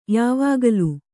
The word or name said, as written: ಯಾವಾಗಲೂ